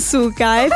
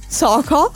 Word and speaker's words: Soko: E sembra che abbia lasciato la sua ex, Alicia, e adesso sta con un artista che si chiama Soko. Non Suka, figlio. Si chiama Soko.